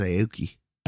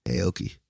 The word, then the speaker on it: Aoki: Devon Okoye. She's the right here. But yeah. – Hey, no, it's Aoki. Aoki. Like Steve Aoki.